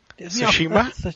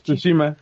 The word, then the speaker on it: Tsushima: Tsushima. – Tsushima.